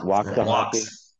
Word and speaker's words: Waxahachie: You're in Waxahachie. Did I say that right? Waxahachie. – Waxahachie.